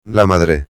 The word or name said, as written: la madre